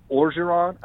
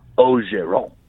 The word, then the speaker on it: Orgeron: I said, I've heard Orgeron. I've heard Ogeron. I said, how do you say it? And he said, well, in Cajun, it's Ogeron. – I said, Ogeron. – Ogeron. So I go Ogeron because it's the closest thing to it.